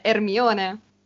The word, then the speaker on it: Hermione: Tu prima hai nominato Hermione, ma da noi è Hermione.